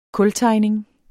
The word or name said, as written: kultegning